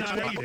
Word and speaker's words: Ada: Why is it Ida? It's ADA. – I think it's Ida. – I don't believe that. You're telling me they pronounce ADA, Ida? – I'm almost certain. It is Ida, Oklahoma.